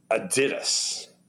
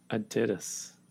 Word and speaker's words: Adidas: Do you know we have been pronouncing that name wrong all these years? The name, because it's a German company, the name, it's actually pronounced Adidas. – Adidas. – Adidas. Don't that's how that. You print that. That's the pronunciation.